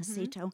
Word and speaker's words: Acito: Opening night tonight with Birds of a Feather by Marc Acito. Is that how you say it? – Mm-hmm. – Acito.